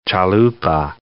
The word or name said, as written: chalupa